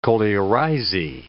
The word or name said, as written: coleorhizae